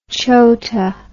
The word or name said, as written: chota